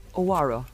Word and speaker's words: oyaore: Now, we know Irish love a vowel, but this is spelt O-Y-A-O-R-E. It's Luo. O-Y-A-O-R-E. Go ahead. – Owaro? Owaro? – O-Y-A-O-R-E. – Owaro.